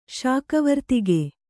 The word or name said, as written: ಶಾಕವರ್ತಿಗೆ